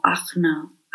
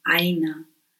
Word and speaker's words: Ajna: En la zona del entrecejo está el chakra Ajna, que también se le conoce como Ajna, Aina, ¿sí? Dependiendo de la tradición lo vamos a pronunciar de una forma u otra. Este chakra en el entrecejo es el encargado de todo el sistema endocrino y del sistema respiratorio superior.